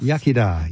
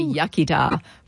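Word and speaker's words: iechyd da: Yachida. – Yeah, the English say yachida, but it's yachida, good health.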